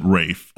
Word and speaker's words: Ralph: The clip I sent you, they're actually interviewing him, and he gives a little funny story about how the British spell that name like Ralph, but they pronounce it Rafe.